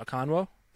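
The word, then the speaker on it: Okonkwo: I don't want to butcher his last name, Okonwo. How you pronounce the name? – It sounds right. It sounds right to me.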